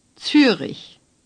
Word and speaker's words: Zürich: Hamburg, Bremen, Berlin, Hannover, Dortmund, Essen, Düsseldorf, Köln, Frankfurt, Stuttgart, München, Wien, Zürich. – You notice that they sound quite different from the way you usually hear them in English, but many letters are pronounced the same as in English.